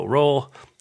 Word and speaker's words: Rowell: Recent favorites: Carry On by Rainbow Rowell, Sleeping Giants, Rowl rhymes with towel.